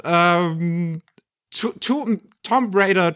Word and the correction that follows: Tomb Raider: To, wie, wie spricht man das nochmal aus? – Tomb Raider. Tomb Raider.